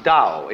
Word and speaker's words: Dao: That's pronounced Dao. If it had an apostrophe after it, it would be pronounced Tao. But Dao is more or less the right pronunciation for that word.